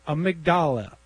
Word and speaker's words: amygdala: It's amygdala.